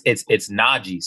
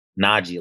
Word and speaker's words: Najee: So Najee, like, you listen to, you probably don't listen to saxophone music, but there's a saxophone player named Najee. And that's how you pronounce my name. It's not hard. It's Najee, you know.